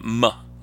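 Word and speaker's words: MØ: Yeah, I think I think she pronounces her name Moh.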